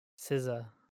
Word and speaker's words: SZA: I think if I'm reading this phonetic pronunciation properly. – Really? – It's capital SIZ-UH. That'd be SZA or SZA? – SZA. – SZA. – SZA. – Okay, that makes a little more sense. SZA.